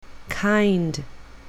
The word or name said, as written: kind